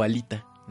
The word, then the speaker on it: Alita: Eh, la película va a ser protagonizada por Rosa Salazar con el protagónico en el papel de Alita o Alita, como le quieran pronunciar. Este, es que le falta el acento, no es esdrújula para nosotros, entonces pues, yo lo leo Alita, ¿no? – Como las alitas, ¿no?